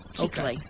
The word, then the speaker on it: Kuechly: You're looking up how to pronounce Kuechly. – Is it Kuechly? – Keek. K-E-E-K. – Well, I knew I was butchering it. – Kuechly.